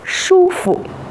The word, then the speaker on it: 舒服: 舒服，第二个字本来读作服，但是在这个词语当中我们读作轻声，请大家跟我读：舒服、舒服。